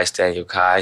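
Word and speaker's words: Staniel Cay: And the actual island we went to with the pigs was uh, Staniel, I don't know how you say it, Kai, Staniel Kai, C A Y Island.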